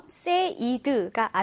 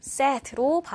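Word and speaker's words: said: 그래서 pay, paid, paid, aid 이렇게 발음이 되는데 특이하게 say 같은 경우에는요, say 같은 경우에 이 발음이 sad, sad로 발음이 됩니다. 자, paid처럼 said가 아니라 set로 발음된다라는 거꼭 기억하면 좋겠습니다.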